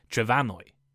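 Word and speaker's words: Trefynwy: And a few centuries ago, the M in Welsh somehow changed to an F, pronounced like a V, making the modern Welsh name Trefannwy.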